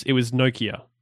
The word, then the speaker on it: Nokia: There was a video online that gave me the correct pronunciation, and it was Nokia. So, emphasis on the no, Kia.